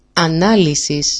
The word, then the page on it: analisis: ana-lisis